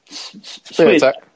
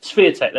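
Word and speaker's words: Świątek: I wonder if we should start with Swiatek. I can't say her name properly. – Swiatek. – Okay, Swiatek, there we go, with the V.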